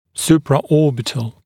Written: supraorbital, supra-orbital

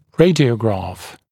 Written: radiograph

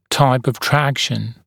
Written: type of traction

